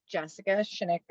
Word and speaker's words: Jessica Shinnick: Did I say that right? – That is correct. – All right. Welcome to the show. Do you want to introduce yourself? – Sure. My name's Jessica Shinnick.